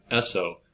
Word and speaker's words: Esso: Jersey Standard marketed its products under the brand Esso, which is the phonetic pronunciation of the initials S and O in Standard Oil.